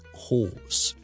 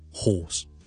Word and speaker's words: hoarse: His voice is a bit hoarse。咁你可以话，譬如呢个病可能会令到把声沙咧。咁你话呢个 illness 咧 might cause hoarse voice。发音啦，同一只马个 horse 咧系一模一样嘅，所以咧 horse。